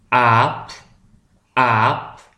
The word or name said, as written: de aap